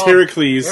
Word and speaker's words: Heracles: It's you know, the Greek pronunciation. Like, it's Heracles. – Heracles, there you go.